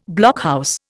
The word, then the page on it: blockhouse: Indian